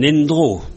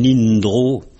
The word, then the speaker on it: endro: On l'entend quelquefois à la radio, hein, un endro, mais on l'entend prononcer un indro. Bon, ça s'écrit un endro, E-N-D-R-O. Le milieu, l'environnement.